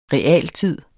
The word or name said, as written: realtid